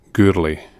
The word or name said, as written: Gourlay